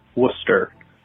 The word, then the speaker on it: Worcester: You've mispronounced it wrong a few times. It's Worcester. Very simply, Worcester. And then I would call it Worcestershire sauce.